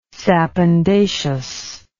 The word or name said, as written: sapindaceous